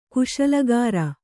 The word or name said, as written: ಕುಶಲಗಾರ